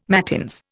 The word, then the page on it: mattins: Indian